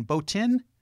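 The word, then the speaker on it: Botín: It's spelled B-O-T-I-N, Botin? – Botin.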